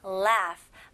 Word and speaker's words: laugh: 발음은 laugh. 마지막 G, H는 F 발음으로 하셔야죠.